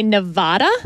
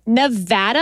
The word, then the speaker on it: Nevada: because a lot of politicians would say Nevada. – Wrong. – And that is wrong. – Wrong. It is Nevada. And I had to train myself to say Nevapple so I could pronounce the A right.